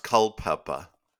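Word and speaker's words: Culpepper: It's pronounced Culpeper.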